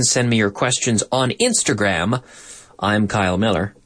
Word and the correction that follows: Millar: I'm Kyle Millar. Millar.